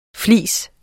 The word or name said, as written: fleece